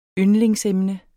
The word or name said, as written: yndlingsemne